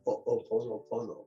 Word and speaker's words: Ho'oponopono: who is the woman who brought back Ho'oponopono. And Ho'oponopono was spelled like this, H-O with an apostrophe. The apostrophe is to hold the breath, Ho-O. You'll say Ho-O, yeah. Ho-O, H apostrophe, O, Ho-O, and then apostrophe, P-O-N-O, apostrophe, P-O-N-O.